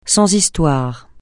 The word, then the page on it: sans histoires: francais